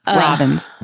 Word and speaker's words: Robbins: Because sometimes people pronounce my last name wrong. And it's like a literal... It's a word. – It's a bird. It's a word. Right. Yeah. Robbins. If you're wondering what it is, it's that.